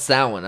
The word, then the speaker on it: Samhain: He's like, it's pronounced Samhain.